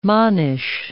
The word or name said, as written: monish